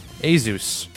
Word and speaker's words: Asus: Asus. A-S-U-S. You know that company. – It's pronounced Jesus. – Sorry. Jesus. – It's called Jesus.